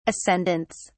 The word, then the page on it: ascendants: British English